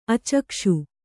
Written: ಅಚ್ಚಾಗಿಸು

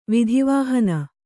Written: ವಿಧಿವಾಹನ

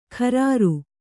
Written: ಖರಾರು

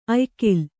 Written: ಆಯಗಾಲು

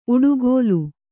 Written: ಉಡುಗೋಲು

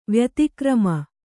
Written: ವ್ಯುತ್ಕ್ರಮ

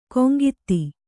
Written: ಗುಂಗುದಿ

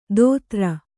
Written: ಧೋತ್ರ

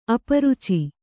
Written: ಅಪರುಚಿ

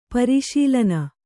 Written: ಪರಿಶೀಲನೆ

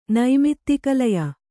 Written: ನೈಮಿತ್ತಿಕಲಯ

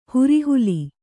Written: ಹುರಿಹುಲಿ

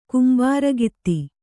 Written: ಕಂಬಾರಗಿತ್ತಿ

